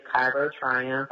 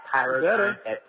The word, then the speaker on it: Cairo Triumph: Yes, so my Instagram name is Cairo Triumph. I can spell it if necessary, or at Cairo Triumph. That's C as in cat, A-I-R-O-T-R-I-U-M as in mother, P-H.